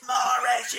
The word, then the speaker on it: Mahrez: We're just screaming at each other, going, "No, it's Mahrez, you bastard!